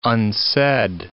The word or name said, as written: unsaid